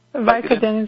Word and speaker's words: Vicodin: Anybody know how to spell that? – Vicodin is right.